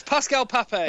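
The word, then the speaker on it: Pascal Papé: Pascal Papp? Or whatever his name is? – Yes, JB, it's Pascal Pape. – Oh, I thought I butchered that.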